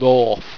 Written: rhorf

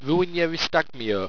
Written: ruenyeriztagmeo

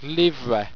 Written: livra

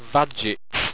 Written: vagits

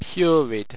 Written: purid

